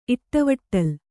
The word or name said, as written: ಇಟ್ಟವಟ್ಟಲ್